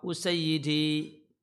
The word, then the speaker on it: al-Usayyidi: untuk Usaidi ada dua, ada dua cara baca yang paling bagus, yang paling populer hamzahnya di domah, sinnya di fathah, dan yaknya di kasrah usayyidi. Ya.